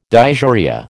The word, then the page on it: dysuria: American English